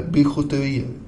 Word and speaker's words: bijouterier: Bioterier, hur uttalas det här? Bijouterier. – Ja, där har du det.